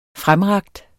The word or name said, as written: fremrakt